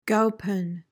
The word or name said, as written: gowpen